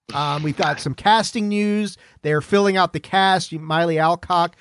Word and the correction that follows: Millie Alcock: I think that's her name, right, Tony? Did I pronounce that right? Correct me. – Millie Alcock? – Yeah, yeah. Millie Alcock, I called her Miley Cyrus.